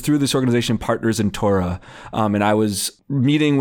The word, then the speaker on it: Torah: So we say Torah, the penultimate stress, and some Jews do say Torah intentionally to try to sound closer to that Israeli norm.